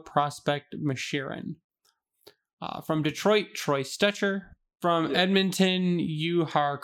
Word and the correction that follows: Jujhar: Kyrra. Kyrra? – Oh, Jujhar Kyrra. – Is that his name? Are you serious? That's how you say his name? Jujhar? – Yeah, Jujhar Kyrra.